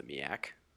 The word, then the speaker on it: MEAC: No, it's actually pronounced the MEAC. – It is pronounced the MEAC. You are correct.